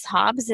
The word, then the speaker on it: Hobbes: One of the things I love that you included was the philosopher Thomas Hobbes.